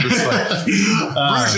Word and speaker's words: Bruges: I say Bruges. How do you say? This is fine. Bruges is perfectly fine.